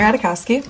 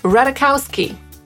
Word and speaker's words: Ratajkowski: Jak ona to wymawia? – I'm Emily Ratajkowski. – I'm Aaron Paul. And we are doing the Wired Auto Complete interview. – Ratajkowski. Tak ona sama to powiedziała.